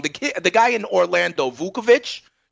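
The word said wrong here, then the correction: Vučević: you know, yes. – Vucevic.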